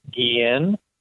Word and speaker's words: Guillen: It's either way. In Spanish, it's Guillen, like the baseball player Ozzie Guillen, or in English, it's Gillen.